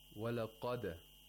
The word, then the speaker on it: walaqad: Okay, in verse 65, we have walaqad. Walaqad. Notice that the waw and the lam and the dal are pronounced with tarqiq, while the qaf is pronounced with tafkhim.